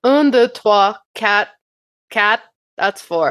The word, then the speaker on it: quatre: Well, that's how the French say four. Quatre. It's quatre. I looked up the pronunciations on YouTube, and then I wrote them out phonetically. Quatre. – It sounds more Spanish. You know what? – Well, this cuatro would be Spanish, right?